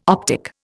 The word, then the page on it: optic: Indian